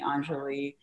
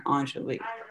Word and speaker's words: Aunjoli: Actually my like great aunt named me Aunjoli. Back in like the 80s, there is this perfume called Aunjoli, but it's spelled E-N-J-O-L-I.